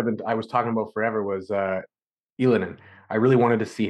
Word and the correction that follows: Ylönen: Ylönen, sorry, I'm I'm trying to get the pronunciation down.